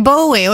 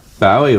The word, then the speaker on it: Bowie: I always thought it was Bowie. I thought, well, it's a Scottish name, must be Bowie. But nobody in Scotland pronounces it like that. Pronounce it Bowie.